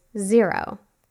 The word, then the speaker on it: Xero: I personally use Xero, which is spelled X-E-R-O, and I love it.